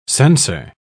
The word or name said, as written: censor